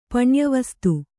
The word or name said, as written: ಪಣ್ಯವಸ್ತು